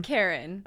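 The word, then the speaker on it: Charon: Karen. – Not manager complaining Karen, a C-H-A-R-O-N Greek figure who ferries people across the river Styx to the afterlife.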